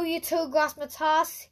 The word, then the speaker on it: Yetur Gross-Matos: No Yetur Gross-Matos.